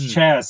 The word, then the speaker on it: Chas: It's Chaz, C-H-A-S.